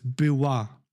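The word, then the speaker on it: była: Das Ding ist, das L, was du vorliest, das wird anders ausgesprochen. Das ist eigentlich ein L mit so einem Strich in der Mitte. – Ja. – Und das heißt Biwa.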